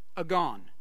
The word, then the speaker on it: agon: The word race in Greek is pronounced agon. Say agon. What word does that sound like? Agony? The word race, we get the word agony from the Greek word race.